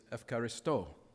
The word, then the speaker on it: ευχαριστώ: It's pronounced differently in modern Greek, but it's spelled the same way. And the word in modern Greek is ευχαριστώ. In the original Greek that we have in the New Testament, it's Eucharisto. Eucharist.